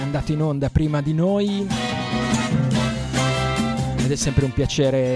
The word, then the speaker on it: Neu: Si scrive Neu, si pronuncia Noi e vuol dire nuova emittente urbana, 7 giorni su 7, 24 ore su 24.